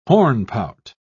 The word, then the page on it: hornpout: British English